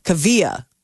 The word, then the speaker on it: Kaavia: She did an Instagram post because uh, I guess people were having a hard time pronouncing it, and it's Kaavia. So that is how you it's uh, that that's how you sp- uh, pronounce it. It's spelled K A A V I A Kaavia James.